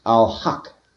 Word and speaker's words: Al-Haq: In the Quran, Allah is said to be, first of all, he has lots of names, and one of the designations is Al-Haq.